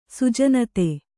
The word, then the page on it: ಸುಜನತೆ: Kannada